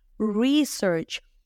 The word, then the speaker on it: research: La palabra investigación también empieza con R, se dice research. Re, empiezan conmigo, re, R suavecita, y luego search, research. Ustedes a lo mejor les suena el verbo buscar.